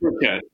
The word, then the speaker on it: Birkett: So your name, first of all, is it Birkett or Birkett? How do you prefer the pronunciation? – It's Birkett.